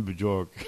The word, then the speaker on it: Bjork: I'm assuming B-J-O-R-K. It's got to be Bjork. – Yeah, Bjorn Bjork. – Yeah, Bjork. The Scandinavian silent J, which is more like a Y.